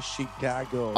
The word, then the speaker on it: Chicago: Did I say it right? Chicago. – Aba, oo naman.